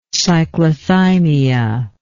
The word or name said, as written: cyclothymia